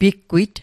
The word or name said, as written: piggwit